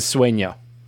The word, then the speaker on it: sueño: I only know that because I've heard so many people pronounce the name of the piece as sueño.